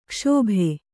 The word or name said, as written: ಕ್ಷೋಭ